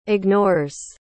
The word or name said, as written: ignores